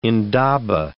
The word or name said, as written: indaba